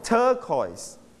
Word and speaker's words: turquoise: Turquoise. Stress on the first syllable.